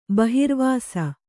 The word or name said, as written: ಬಹಿರ್ವಾಸ